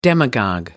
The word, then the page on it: demagogue: American English